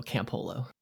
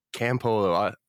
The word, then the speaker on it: Campolo: Yeah, I say it's camp like camping and Olo like water polo, Campolo. – Campolo, great, thank you.